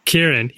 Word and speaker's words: Kieran: Like all anybody could talk about was that the Culkins were staying in town because Kieran Culkin, is that how you say his name? Kieran? – Kieran. Yeah.